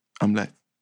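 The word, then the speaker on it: Amleth: Prince Omelette is, and I know that sounds like omelet, but that's how they say it. Omelette.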